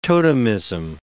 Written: totemism

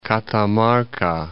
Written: Catamarca

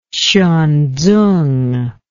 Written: Hsuan Tsung

